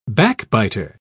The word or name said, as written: backbiter